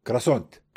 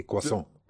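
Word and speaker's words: croissant: كرواسونت. – ما بتقول كرواسون؟ – لا، بتحب تلفظ التي؟ – ما بتحكي الار لما تلفظها، هي كواسون.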